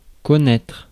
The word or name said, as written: connaître